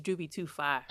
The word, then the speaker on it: Jubi2Fye: His name is Jubi2Fye.